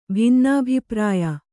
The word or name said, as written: ಭಿನ್ನಾಭಿಪ್ರಾಯ